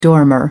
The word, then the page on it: dormer window: British English